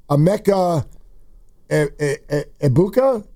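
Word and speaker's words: Emeka Egbuka: Godwin is always dealing with injury, but they've got – And you got to help me pronounce this. There's a rookie with Tampa who everybody is buzzing about. Emeka Ebuka? – Yeah, that's right.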